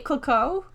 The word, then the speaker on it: Clicquot: How do you say it, Clicquot? – Yes.